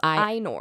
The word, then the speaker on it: Ainur: Oh, also, is it pronounced Ainur or is it pronounced Ainur? – Ainur. And we had said it wrong for the first couple episodes, and then I was listening to the Prancing Pony podcast, and they were like, oh, the Ainur. And I was like, we're idiots.